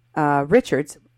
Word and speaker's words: Richards: Francis Sonny Richards. Richard is actually how it's pronounced, as he told me in my interview, because he's French.